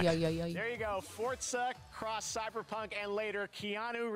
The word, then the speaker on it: Forza: You remember when we used to say Forza? And then Ruben goes, it's actually pronounced Forza.